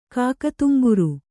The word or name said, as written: ಕಾಕೋದುಂಬರ